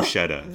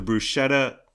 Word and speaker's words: bruschetta: We got bruschetta. – The most disappointing of all. Here we go. – So the bruschetta and... Bruschetta, you mean? – Some people pronounce it bruschetta.